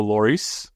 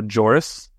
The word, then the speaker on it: Lloris: They do have their new goalkeeper, Hugo Lloris, which is not Hugo Joris, as the Apple TV commentators kept wanting to say. Hugo Joris and Daniel Chaloy. Neither of those is how you pronounce the names.